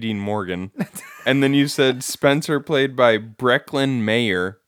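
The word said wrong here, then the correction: Breckin Meyer: His name is Brecken Meyer.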